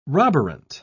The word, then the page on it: roborant: British English